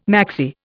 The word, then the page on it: maxi: Indian